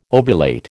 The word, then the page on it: ovulate: American English